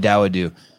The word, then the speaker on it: Dawodu: Dawadu, I think is how you say it. – Thank you. Hakeem Dawadu.